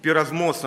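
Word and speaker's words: пиразмос (πειρασμός): Слово «искушение» в греческом языке, «пиразмос» оно так произносится, оно имеет двоякое значение.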